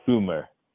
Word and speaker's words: Goomer: Goomer. G-O-O.